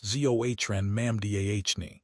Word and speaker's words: Zohran Mamdani: State Assemblyman and mayoral candidate Zoran Mamdani, pronounced Zohran Mamdani, is campaigning on a platform of rent freezes, expanded childcare, and lowering violent crime.